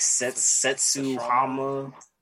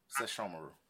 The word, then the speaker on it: Sesshomaru: This is supposed to be Inuyasha and Kagome's daughter with I don't know if I'm pronouncing this wrong, like Setsuhama. – Sesshomaru.